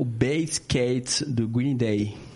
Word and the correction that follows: Basket Case: Basket Case, né?